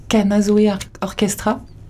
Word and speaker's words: Kanazoé Orkestra: Écoutez le titre Folicadie », et donc l'artiste c'est Kanazoe Orchestra. Je le prononce bien Tout à fait.